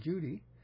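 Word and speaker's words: Cudi: Mount Kudi, though pronounced in Turkish as Judy, in the Kurdish mountains.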